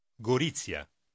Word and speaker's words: Gorizia: Вы помните, как обычно произносится «зета» по-итальянски? Прочтите громко номер 10. – La gita a Gorizia. Gorizia.